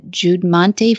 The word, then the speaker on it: Judmonte: I was amazed by this first internship that I read about that you were in your background, that you interned with the Judmonte Farms. How do you say that? Judmont Farms. There we go.